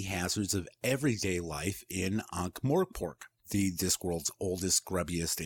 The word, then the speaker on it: Ankh-Morpork: Did I pronounce that right? You're the one who reads the series. – Yeah, I think we went through this last week. But yeah, I'm pretty sure it's Ankh-Morpork.